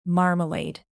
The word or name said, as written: marmalade